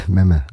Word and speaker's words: Mämä: Habe ich deinen Namen richtig ausgesprochen? Memme? – Ja, ist für, für Deutsche schwierig, aber ja, Memme. Alles sehr kurz, ja. Memme, okay.